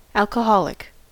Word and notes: alcoholic: American English

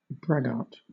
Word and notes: braggart: British English

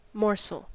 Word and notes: morsel: American English

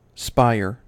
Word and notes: spire: American English

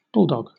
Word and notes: bulldog: British English

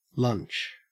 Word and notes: lunch: Australian English

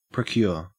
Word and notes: procure: Australian English

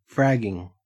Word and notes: fragging: Australian English

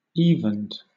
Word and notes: evened: British English

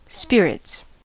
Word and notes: spirits: American English

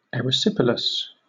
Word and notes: erysipelas: British English

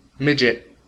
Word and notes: midget: American English

